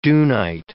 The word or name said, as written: dunite